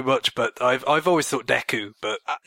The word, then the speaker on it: Deku: To put perspective, I've seen it in katakana as Deku. – Well, there you go. – So that's the Japanese pronunciation, so if you want to assume that it's the same in English...